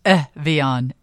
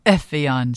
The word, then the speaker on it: Evian: I did just Google it. Evian. Evian. So, Evian. Yeah. – I haven't been saying it right.